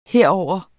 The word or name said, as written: herover